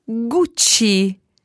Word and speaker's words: Gucci: because I think sometimes when we use these names when we're speaking in English, we don't use the proper Italian pronunciation. So let's hear them again. First of all, there was the one that spelled G-U-C-C-I. – Gucci.